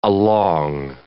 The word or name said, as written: along